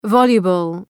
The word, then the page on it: voluble: ‘vɒljəbəl